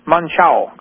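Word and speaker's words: Monção: How do they pronounce that? M-O-N-C-A-O. You speak Portuguese. – Yeah. – Manchao. Good. It's good.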